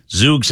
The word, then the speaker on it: Zoox: Z-O-O-X?